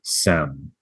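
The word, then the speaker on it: sem (שם): So if the shin is accented on the left, it's going to be pronounced without the H. And so it would be sem.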